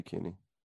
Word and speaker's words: Kinney: And I hope I'm pronouncing that right. K-I-N-N-E-Y. – Yeah, that would be Kenny.